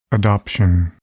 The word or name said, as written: adoption